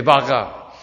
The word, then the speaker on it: Baqa: But is it Baqa or Baq? How do you say it? Baqa? Okay, Baqa.